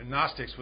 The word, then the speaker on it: Gnostics: The Gnostics with a silent G, and I said they were the sign.